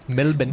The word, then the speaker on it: Melbourne: Melbourne. – But you have to say it like that, Melbourne. You know, like as an American, I feel weird saying that Melbourne.